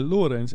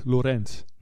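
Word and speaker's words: Lorentz: Lees ik steeds Lorenz. – Nee, dus de klemtoon ligt niet op ens. Dus. – Aha, nou ja, kijk, Lorenz, zo zie je maar.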